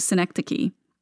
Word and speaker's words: synecdoche: These words that I have to pronounce for you, synecdoche, I have it in my head that it is the New York town Schenectady without the first C, and that is so not right.